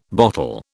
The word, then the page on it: bottle: British English